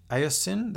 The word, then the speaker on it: Aucoin: Let's head east now to Halifax and Rich Aucoin, that's spelled A-U-C-O-I-N.